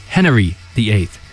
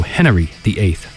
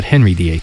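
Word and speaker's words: Henery: And it's actually, no, you got to pronounce it, I am Henry VIII. Because it's H-E-N-E-R-Y. So Henry VIII. I am. Not Henry VIII.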